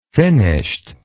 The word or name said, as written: finished